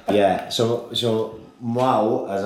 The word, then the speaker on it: Mahou: but I would pronounce it, being a mush, Mahu, but I'm told by Gilly it's pronounced Mau. M-A-H-O-U.